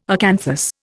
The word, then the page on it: acanthus: Indian